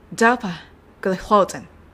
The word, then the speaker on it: ダパグリフロジン: 最初のダパのところは、ダパ、ダパ。なので、ダを強く、パを弱く発音します。それからその後ろのグリフロジンのところは、ローのところのアクセントですね。グリフローズン。合わせますと、ダパ、グリフローゼン。